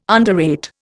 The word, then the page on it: underrate: Indian